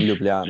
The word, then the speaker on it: Ljubljana: How do you pronounce that properly? Ljubljana. – Ljubljana.